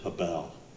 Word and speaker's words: hebel: Used 5 times in verse 2 alone, the Hebrew word for vanity is hebel, H-E-B-E-L, hebel.